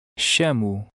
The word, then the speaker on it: chamo: In Portuguese, the CH combination is pronounced like the English SH. Try to read number seven. Remember the sound of the O at the end of a word. – Chamo.